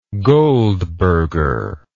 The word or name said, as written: goldberger